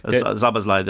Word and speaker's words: Szoboszlai: Well, I think it's like, we've got so many Reds listening to the show, and so, you know, so Szoboszlai, the Hungarian.